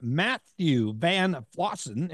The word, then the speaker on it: Matthew VanFossen: Listeners, excited to have Matthew VanFossen.